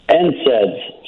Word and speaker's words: NSAIDs: NSAIDs, so non-steroidal anti-inflammatory.